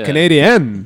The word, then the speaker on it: Canadian: That's how you're supposed to say it. Canadian. The emphasis on the N.